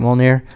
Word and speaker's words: Mjolnir: But that he's the weaponsmith that crafted Mjolnir. And am I saying that right, Mjolnir? – Yeah.